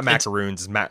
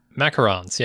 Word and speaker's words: macaron: It's not macaroons. – Macarons, yeah. – Macarons. – Which is my, sure, that's my American pronunciation of a French word.